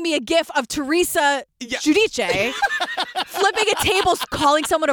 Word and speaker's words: Giudice: She calls herself Teresa Giudice, but it's Giudice. That's how you say it, Italian.